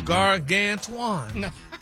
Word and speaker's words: gargantuan: That sounds like you missed a syllable there, sir. – Oh. Gargantuan. – No, sir. Nope. – Maybe you should just make some more videos with you with different clothes on with Stephanie Mills music. Like you been doing. – Look at the breakdown of the word. – It's pronounced gargantuan.